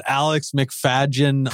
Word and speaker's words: Alex McFadyen: We've got Alex McFadyen program.